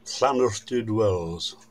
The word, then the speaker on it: Llanwrtyd Wells: Llanwrtyd Wells.